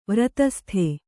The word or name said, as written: ವ್ರತಸ್ಥ